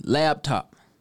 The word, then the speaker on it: laptop: Notice there is no B. Let's say it together. Laptop. One more time. Laptop. L-A-P, top, not L-A-B-T-O-P, laptop.